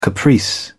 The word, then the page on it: caprice: British English